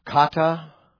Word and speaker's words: kata: According to the revelation of the mystery is kata apokalupsin. I'll spell it for you. It's two words. Kata is K-A-T-A, which means with regard to, by, or because.